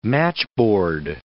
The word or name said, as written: matchboard